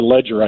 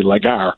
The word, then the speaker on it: Legere: I mean, I like the CEO of T-Mobile in particular, John Legere. Or Ledger, I guess he pronounces it, even though it looks like it should be Legere.